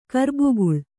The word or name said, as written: ಕರ್ಬುಗುಳ್